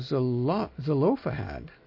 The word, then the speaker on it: Zelophehad: Then the daughters of Zelophehad, actually, would be the pronunciation with the accent on the O.